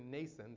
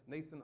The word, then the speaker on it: Mnason: Also, some of the disciples from Caesarea went," I'm losing my voice, "went with us and brought with them a certain Mnason," the M is silent, "Mnason of Cyprus, an early disciple with whom we were to lodge."